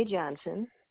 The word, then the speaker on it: Johnson: My name is Naomi Johnson.